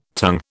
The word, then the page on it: tung: British English